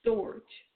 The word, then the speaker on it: storge: So we're going to start with the first love, storge. And I'm going to spell it for you. S-T-O-R-G-E.